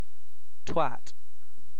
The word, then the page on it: twat: British English